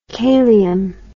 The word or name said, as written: kalium